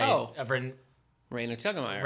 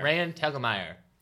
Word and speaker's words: Raina Telgemeier: I finished Ghost by Raina Telgemeier. – Raina Telgemeier.